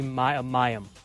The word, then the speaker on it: Mayim: Ken Jennings going to have to split hosting duties on Jeopardy with Maheem Bialik. Did I say that right? – I believe Mayim. – Yeah, Mayim Bialik.